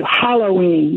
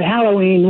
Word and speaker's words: Halloween: The upcoming holiday is spelled H-A-L-L, correct? O-W-W-E-N? – Yep. – But it is certainly these days pronounced as if it were H-O-L-L-O-W, Halloween, you hear, instead of Halloween, which is how it was always pronounced, and that's how it's spelled.